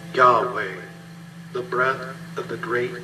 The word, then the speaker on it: Yahweh: But the Hebrew would be pronounced something like Yahweh, expressing the word for I am.